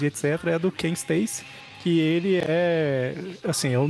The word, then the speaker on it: Stacy: É, na verdade, eu acho que nem pronuncia Stacy, porque é S-T-E-A-C-Y. Eu não sei como é que pronuncia, João. Que é o Stacy com E antes do S. Seria Stacy mesmo.